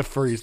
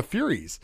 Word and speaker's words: Furies: The Furries, the Furies. Yes, I kept saying it wrong. I called it the Furries.